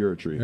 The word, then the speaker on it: Erdtree: That's all the Shadow of the Erdtree? – Shadow of the Erdtree.